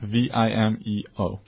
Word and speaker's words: Vimeo: Vimeo.